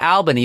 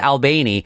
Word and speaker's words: Albany: You don't pronounce it Albany, you pronounce it Albany. Mm.